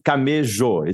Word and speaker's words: Camejo: It's with an O at the end. – Oh, but Camejo. is it spelled with an A or no? – No, it's not. – Spelled with an O. – Yeah. C-A-M-E-J-O. Camejo. – M-E-J-O. That's my fault. I wrote that down. – Okay. No, but you pronounce the J. It's a hard J.